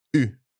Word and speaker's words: eu: For years, I pronounced this wrongly. I used to say j'ai eu, but no, it's j'ai eu. I have had. J'ai eu. – J'ai eu. So E-U is pronounced e, mm-hmm. except for the past participle of avoir, which is eu.